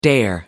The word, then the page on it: dare: American English